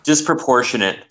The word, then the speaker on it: disproportionate: And Black Panther's saying, what'd you call that rage disappropriate? It's... disappropriate. Is that the right way to say it? Okay, I'm having problems here. – Disproportionate. – Disproportionate. Oh, that's right.